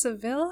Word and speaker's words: Seville: Is Seville how you pronounce this next one? – Yeah, like I think the fancy oranges? – Yeah.